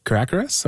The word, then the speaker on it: caracara: There's the example, I'm afraid I'm going to say this wrong, but the two crested caracaras or caracaras? – Crested caracara.